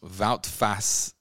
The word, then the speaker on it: Wout Faes: Was this David Luiz's twin, Woodface? – Wout Faes, yes. – Is that how you say his name? Wout Faes.